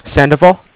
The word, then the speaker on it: Sandoval: Sandoval. – Can you spell that? – S-A-N-D-O-V-A-L.